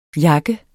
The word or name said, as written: jakke